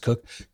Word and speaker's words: Coke: And Cook, I know it's spelled Coke, but it's pronounced Cook.